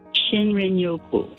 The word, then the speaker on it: shinrin-yoku: Yeah, I think it's called shin-yoku. Is that how you pronounce it? The Japanese term for wood air bathing. – Shinrin-yoku.